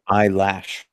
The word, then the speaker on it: Eilish: I believe it's pronounced eyelash.